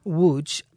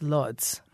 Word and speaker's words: Łódź: She was living in one of the major cities in Poland, Łódź. That's the Polish pronunciation, but a lot of people know it as Lodz, okay. – L-O-D-Z.